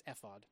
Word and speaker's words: ephod: Okay, so David, wearing a linen, do you pronounce it ephod? I think it's ephod, yeah.